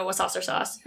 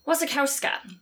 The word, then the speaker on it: Wasikowska: We also have, I should learn how to pronounce her name properly, not Mia Wasasser-Sauce. That's Carly's name. – That's the reason. – Mia... Wasikowska,